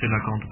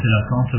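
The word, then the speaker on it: cœlacanthe: Mais si on prend le train du colocante, qui est effectivement un animal marin. – On prononce aussi Célacanthe, parfois. – Célacanthe, oui.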